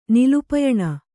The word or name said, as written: ನಿಲುಪಯಣ